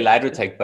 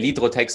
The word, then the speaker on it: Lidrotec: it's just easier in English to say Lidrotec, but in German we actually say Lidrotec, so both work fine.